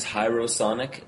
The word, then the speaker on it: Hierosonic: The band name is Hierosonic. That's like hieroglyphics.